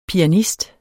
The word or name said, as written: pianist